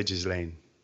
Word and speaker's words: Ghislaine: She's French, so I say Ghislaine.